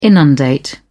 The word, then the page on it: inundate: British English